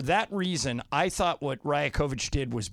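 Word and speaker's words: Rajaković: Rajakovic.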